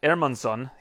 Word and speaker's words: Hermansson: So, this weekend, Jack Hermansson, he told me that's how you pronounce it in his native Sweden, taking on Marvin Vettori.